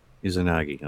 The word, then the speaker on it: Izanagi: I think it's Izanagi is how it's spelled. Is that or how it's pronounced? – Yeah, that's how it's pronounced. – Izanagi, okay.